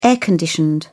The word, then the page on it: air-conditioned: British English